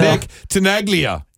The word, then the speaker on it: Nick Tenaglia: Nick Tenaglia.